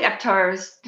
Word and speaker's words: Aptar: So, anyway, hopefully Aptar's, did I say it right? – Yeah, Aptar, A-P-T-A-R.